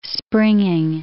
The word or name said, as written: springing